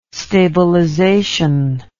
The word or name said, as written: stabilization